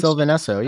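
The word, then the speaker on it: Sylvan Esso: And one of them is part of, and I'm going to mangle this, but Sylvain Esso, I think that's how it's pronounced. – Oh, Sylvain Esso. Yeah, yeah.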